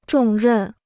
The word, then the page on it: 重任: zhòng rèn